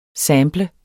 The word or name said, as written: SAMPLE